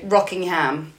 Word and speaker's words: Rockingham: Which is spelled like rocking chair, but like Rockingham. – Yeah. – So the Aussies say Rockingham.